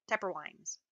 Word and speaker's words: Toepperwin: But it's spelled T-O-E-P-P-E-R-W-I-N. But it's pronounced Tepperwine.